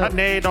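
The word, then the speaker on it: Sinéad: I think it's Sinead. – Of course you do. – You have to say it like you're saying shillelagh. I'm a shillelagh. So, Sinead O'Connor.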